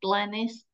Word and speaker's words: Glenys: I'm just Glenys Nellist, and it's a very honestly, I think I'm the only Glenys Nellist in the whole wide world.